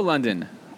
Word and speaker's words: London: Hello, London. London, did I say it right? – Yes.